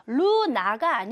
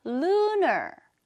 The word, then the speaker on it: lunar: 발음은 Luna가 아니고 Lunar, Lunar, Lunar New Year's Day, 음력 1월 1일이 되는 거죠.